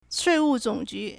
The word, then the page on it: 税务总局: Shuìwù Zǒngjú